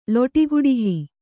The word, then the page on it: ಲೊಟಿಗುಡಿಹಿ: Kannada